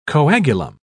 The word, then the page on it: coagulum: British English